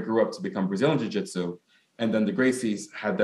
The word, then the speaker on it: Jiu Jitsu: How and is it and how or is it different from Japanese uh, Jiu Jitsu, if I'm pronouncing that right? – Yes, totally.